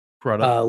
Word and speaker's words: Crudup: But it is interesting that with Billy, how do you say his last name? Crudup? – Yep. – Crudup.